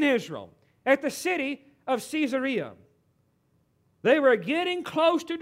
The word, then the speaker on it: Caesarea: Some of you may pronounce this city Caesarea, but I was corrected when I was in Israel. They don't pronounce it that way. They pronounce it Caesarea because it's named after Caesar.